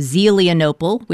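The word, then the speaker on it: Zelienople: Angela Autumn is from just outside Pittsburgh, PA, in the Appalachian town of Zelianople, which, yes, I am patting myself on the back for pronouncing it correctly to all my Zeely fans.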